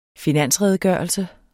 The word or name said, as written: finansredegørelse